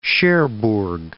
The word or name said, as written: Cherbourg